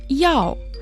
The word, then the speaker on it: 药: Слово «яо» произносится также в четвертом тоне.